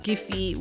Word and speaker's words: Giphy: Giphy is spelled G I P H Y, like, you know, a GIF, um, but, you know, Giphy.